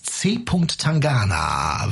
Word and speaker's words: C. Tangana: Und hier ist ein Song von, ja, wie spricht man den aus? C. Tangana. Weißt du das, Detlef? Ja, das ist korrekt.